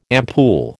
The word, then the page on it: ampoule: American English